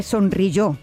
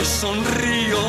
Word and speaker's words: sonrió: Dios me vio tocando ante él, me sonrió. Así se dice las cosas, Rafael. Me sonrió. no me sonrío.